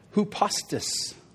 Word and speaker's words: hupostas: It's pronounced hupostas. Hupostas.